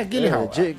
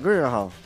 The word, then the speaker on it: Gyllenhaal: Gyllenhaal, é esse o nome? É Gyllenhaal? Gyllenhaal. – Eu também não sei produzir pra pronunciar, não, cara. – É, Gyllenhaal. – Gyllenhaal. – É ele mesmo.